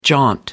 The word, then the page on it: jaunt: American English